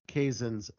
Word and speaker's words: Kazan: Another highlighted film, Dean, I didn't get to see this one, was Carey Mulligan and Zoe Kazan's She Said. – Kazan. – Zoe Kazan. Okay, I've heard both pronunciations, but I guess that will be debated for another one.